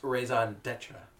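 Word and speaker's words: Raison D'Être: So let's get into another beer from the cellar, and the Raisin Nestra, I think, is up next. – Actually, Alex, it's pronounced Raisin Detra.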